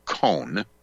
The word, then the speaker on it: Cohn: His name is Gary Cohn, C-O-H-N, or Cohn.